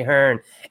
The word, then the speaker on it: Ahern: It is Wednesday night, and we're here live on Meeple Syrup with our special guest, Julie Ahern.